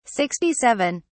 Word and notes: 67: British English

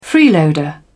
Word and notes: freeloader: British English

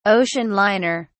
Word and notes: ocean liner: British English

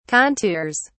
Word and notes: contours: British English

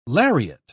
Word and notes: lariat: British English

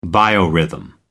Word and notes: biorhythm: American English

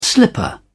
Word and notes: slipper: British English

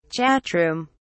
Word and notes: chatroom: British English

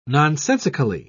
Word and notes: nonsensically: British English

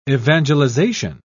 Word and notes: evangelization: British English

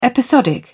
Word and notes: episodic: British English

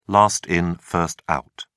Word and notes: last in first out: British English

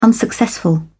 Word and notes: unsuccessful: British English

